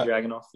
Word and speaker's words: Dragunov: Yeah, Ilya Dragunov.